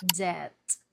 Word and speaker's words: debt: Debt. Debt. The B is silent.